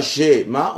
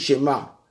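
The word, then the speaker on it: Shema (שמע): Quand on prononce le mot Shema, on ne dit pas Shema, on dit Shema. On met l'accent tonique sur la deuxième syllabe.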